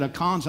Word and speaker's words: Achan: And what had happened, a guy named Achan is how we say it. Achan's how they say it in Hebrew.